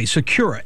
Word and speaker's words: Sakura: Okay, Secura. Spell that for me. – S-A-K-U-R-A.